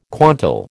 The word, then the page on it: quantal: American English